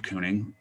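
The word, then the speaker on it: Keuning: But, you know, it's K-E-U-N-I-N-G is what the band name is. – Or you could just respell it phonetically, K-O-O-N-I-N-G.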